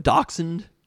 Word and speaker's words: Dachshund: Isn't it pronounced Dachshund? Am I wrong about that? – No, that probably says right.